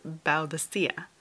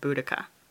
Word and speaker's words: Boudica: All that to say, I'll be going with Boudica, as that seems to be the accepted pronunciation as far as I can tell.